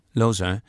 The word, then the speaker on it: Lausanne: And this was so cool because we first of all had never been to Lausanne. Is that how you pronounce it? – Lausanne.